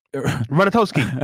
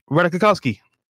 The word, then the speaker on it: Ratajkowski: Ratatos. Ratatowski. – Ratajkowski.